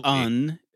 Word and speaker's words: Pagan: That could be Pagan. That could very easily. – Pagan. – Yes. Look at no, P-A-G-A-N.